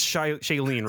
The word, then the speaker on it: Shailene: So it's Shailene, right? – Yeah, it is.